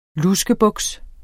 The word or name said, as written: LUSKEBUKS